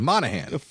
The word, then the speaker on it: Monaghan: Is it Monaghan? – Yeah, he's full-time. Monaghan. – The G is silent. No G in there.